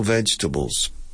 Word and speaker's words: vegetable: Both comfortable has five syllables. Four syllables. Comfortable. Comfortable. También en español tiene cuatro sílabas. Vegetable. Vegetable. Four syllables. But when we speak, we never use four syllables. We reduce it to three.